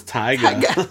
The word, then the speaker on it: taiga: And they were, how do you say T-A-I-G-A? – Taiga? – Taiga? – I mean, that's how I'd say it.